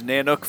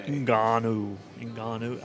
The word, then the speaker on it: Ngannou: So you were going to watch the Fury versus Nanook fight today. – Ngannou. I don't even know if I pronounce it right. But yeah, Ngannou, who's not a boxer.